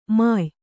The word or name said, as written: ಮೆಯಿ